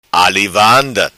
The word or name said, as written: vanda ali